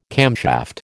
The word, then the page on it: camshaft: American English